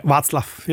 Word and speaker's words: Václav: Yeah, Václav.